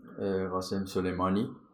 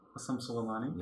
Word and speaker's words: Qasem Soleimani: Am I saying that right? – Qasem Soleimani. – Qasem Soleimani.